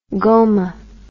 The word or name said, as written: goma